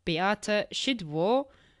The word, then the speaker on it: Beata Szydło: Because I literally just learned how to say the name of your last prime minister, Beata Szydło.